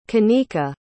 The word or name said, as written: Kanika